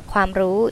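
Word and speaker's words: Kwam ru (ความรู้): Yes, and how you pronounce it. – Kwam ru. – Kwam ru, chai ka.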